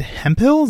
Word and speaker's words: Hemphill: My name is Adam Wathan, your host, as always, and today I'm here with David Hemphill. Is that how you pronounce it? I should have asked you before. – Yeah, that's great. It's like hemp on a hill.